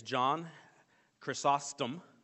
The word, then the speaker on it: John Chrysostom: The next one is John Chrysostom.